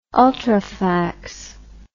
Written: ultrafax

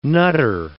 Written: nutter

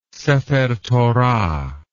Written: sephertorah

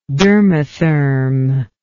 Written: dermatherm

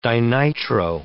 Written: dinitro

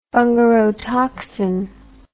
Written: bungarotoxin

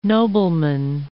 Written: nobleman

